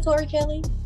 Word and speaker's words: Tori Kelly: Yeah, is it Tori Kelly? Yeah, that's how you say her name.